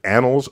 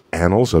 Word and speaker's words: Annals: Annals of Improbable Research. Annals of Improbable Research. – Which you have to pronounce very carefully.